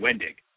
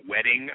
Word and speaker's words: Wendig: Chuck Wendig is on the, did I get the name right, Chuck? – You got the name right, Wendig.